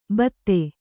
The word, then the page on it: ಭೀತೆ: bhīte; Kannada